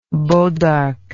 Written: bois d'arc

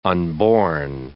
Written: unborn

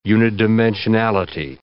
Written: unidimensionality

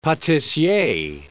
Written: patissier

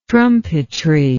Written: trumpetry

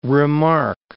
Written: remarque

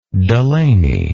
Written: Delany

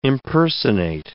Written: impersonate

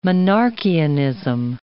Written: Monarchianism

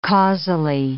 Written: causally

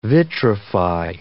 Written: vitrify

